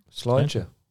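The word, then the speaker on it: Slàinte: Slentje. Slantje. – Oh, zo moet je het uitspreken. Oh, Slantje.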